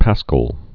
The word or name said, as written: Paschal celery